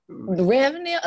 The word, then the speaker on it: Ravenel: Ravenel, unless you're in South Carolina or North Carolina, where that Ravenel name comes from, that's the only time people get it right.